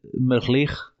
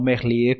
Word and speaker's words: Merlier: A primeira etapa, como já dissemos, Julian Lafilippe, segunda etapa para Matthew Van Der Poel e a terceira etapa para Tim Merlier acho que é assim que se diz, e não Merlier, como nós dizemos, de uma forma mais afrancesada, mas sim de, de, puxando pelo, pela pronúncia local, será Merlier.